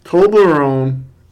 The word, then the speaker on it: Toblerone: Toblerone? Is that how you pronounce it? – Yes.